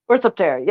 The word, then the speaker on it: Orthoptera: What, did I say it wrong again? – No, it's it right, that's what I'm doing now. Oh yeah, okay. Sorry, that was fun. That was fun. – Orthoptera.